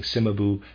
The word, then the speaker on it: Cimabue: Readers aside, I am pronouncing Cimabue, C-I-M-A-B-U-E. I apologize for my pronunciation, Cimabue.